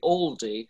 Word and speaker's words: Oldie: By the way, were you aware that you pronounce Oldie, Aldie, like the supermarket? – we're very pro-Oldie, so that's right.